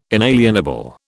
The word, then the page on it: inalienable: British English